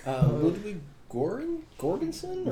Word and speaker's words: Ludwig Göransson: Ludwig Göransson? – Very good.